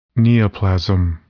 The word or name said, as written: neoplasm